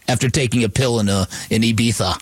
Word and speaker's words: Ibiza: So if Lamar Jackson, if Josh Allen, if Aaron Rodgers, if these dudes wanted to spend time in Ibiza this summer, you probably want to cancel those vacation plans.